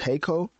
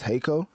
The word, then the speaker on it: Jhayco: As an artist who I'm not familiar with, and hopefully I say his name right, but I think it's Jhayco. I think the J is silent. So it's Jhayco.